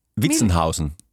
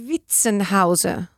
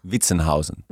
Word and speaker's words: Witzenhausen: Witzenhausen. – Miljuschka. Miljuschka. – Miljuschka. Witzenhauser. – Witzenhausen.